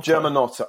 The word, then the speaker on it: Germanotta: So, just quickly, we've got three pieces about the girl born Joanne Germa Jotta. If I pronounce it, I can't pronounce it. – Isn't it Germanotta? – Germanotta. All right, let me say that again. The girl born Joanna Germanotta.